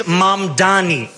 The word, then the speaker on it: Mamdani: The name is Mamdani, M-A-M-D-A-N-I. You should learn how to say it.